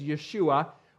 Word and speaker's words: Yeshua: That's what she says, Yeshua, which is the Hebrew pronunciation of the name Jesus.